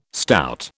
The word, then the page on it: stout: British English